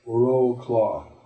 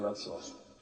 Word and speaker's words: Wrocław: If you see that word, it looks like it's pronounced Roclaw, but it's actually pronounced Wrocław.